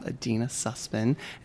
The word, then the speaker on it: Adeena Sussman: We have Adeena Sussman and Raquel Pelzel.